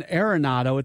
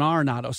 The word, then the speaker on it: Arenado: It's not Nolan Arenado, it's Nolan Arenado.